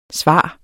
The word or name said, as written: svar